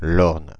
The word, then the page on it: Orne: Paris; [ɔʁn]; [l‿ɔʁn]